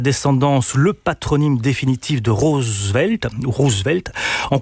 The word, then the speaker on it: Roosevelt: Parce que à partir de Nicolas, des enfants de Nicolas plus exactement, la famille Roosevelt, vous l'avez très bien prononcé, ça se dit Roosevelt et non pas Roosevelt comme on est tenté de le faire dans un pays francophone, c'est le moment où la famille prend sa singularité historique.